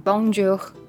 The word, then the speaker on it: Bonjour: 大家讲 Bonjour 是不是都变从中文的那个一些字来看，就变成就变成讲 Bonjour Bonjour，然后少了少了什么字呢？诶，就是最后最后一个 h 的发音，因为平时法文它它一个 Bonjour，它应该要讲 Bonjour，就是会有一个 h 的音在最后面，那个东那个那个字那个字就是英文的 r 嘛，那法文就念 h，那。